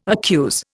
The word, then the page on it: accuse: Indian